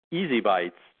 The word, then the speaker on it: ESI Bites: You can remember our show and website phonetically as Easy Bites, the way it sounds out, of ESI information.